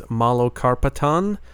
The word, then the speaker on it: Malokarpatan: The band is Malokarpatan. At least that's how I've heard it pronounced.